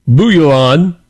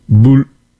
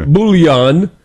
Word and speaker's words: Bouillon: Geoffrey of Bouillon. I didn't say that right. Bouillon. Bouillon.